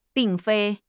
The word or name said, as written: 并非